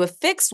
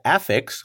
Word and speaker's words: affix: Affix can also be a noun pronounced affix, which means a part of a word that you add on to another word, like a suffix or a prefix, not to be confused with a prefix, which is my favorite way to be a cheapskate in French restaurants.